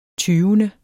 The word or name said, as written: tyvende